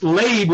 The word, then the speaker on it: Leib: It's spelled with two yuds, even though it's pronounced Leib with one yud.